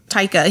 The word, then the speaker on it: Taika: Tika Waititi, or how do you say his name? – Taika.